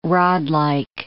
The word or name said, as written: rodlike